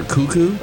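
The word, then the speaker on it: Koekkoek: Wait, his name is Slater Cuckoo? – Well, that's how his last name is pronounced. It's got a funky spelling, but that's how it's pronounced.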